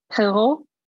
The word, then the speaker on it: Perrault: Perrault. Yeah. So yeah, you wouldn't pronounce the consonants at the end.